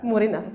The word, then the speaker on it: Murina: Jel sam dobro akcentovao? – Jekaterina Murina. – Murina, da.